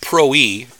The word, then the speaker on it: proe: The Greek word proe has two syllables, like our word holy, but accented at the end.